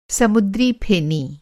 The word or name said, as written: समुद्रीफेनी